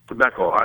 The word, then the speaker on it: Beckel: Before we went to the news break, we were talking about a commentator on Fox by the name of Bob Burkle. – Beckel. I mispronounced it. It was okay. Beckel.